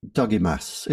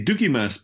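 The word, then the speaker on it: Dougiamas: I always say Dougimas because in Scotland there's lots of men called, you know, Dougie or Dougie, you know, that's a common term in Scotland, but I may be completely wrong in that, so you're asking the wrong person.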